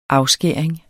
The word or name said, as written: afskæring